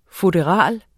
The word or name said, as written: FUTTERALERNES